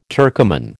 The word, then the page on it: Turkoman: American English